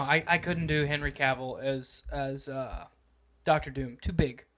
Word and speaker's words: Cavill: It's Cavill.